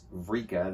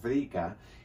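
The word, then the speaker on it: vrika (βρήκα): Okay, you can see that E-U-R-E right there. So eureka comes from evrika, which means I have found it. In modern Greek, it's just vrika. They've cut off the E at the beginning. It's just vrika.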